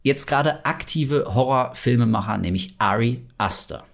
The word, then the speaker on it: Ari Aster: Aber nein, er wird ausgesprochen Ari Aster.